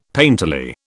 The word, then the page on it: painterly: British English